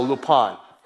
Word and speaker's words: Lupin: It's Lupin, but it's in French, so Lupin. That's how they say it.